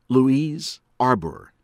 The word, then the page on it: Louise Arbour: American English; from Canada